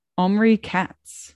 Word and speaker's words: Omri Katz: Omri Katz.